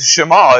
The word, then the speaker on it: Shema: We would say Shema. I would say Shema, but I'm from East Texas, not from the Near East. It's Shema is the correct pronunciation of that.